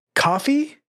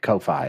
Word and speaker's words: Ko-fi: Coffee? – That's called Ko-Fi is how it's pronounced, according to them.